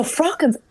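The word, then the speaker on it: fraughan: And listen, what are frockens? – Oh, so frockens. I don't know. It's not a Wicklow thing only, but it's F-R-A-U-G-H-A-N is how it's spelt.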